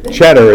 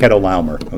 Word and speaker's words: Chedorlaomer: Cheddar, it's not. It's actually Ked is how you pronounce it. Chedorlaomer.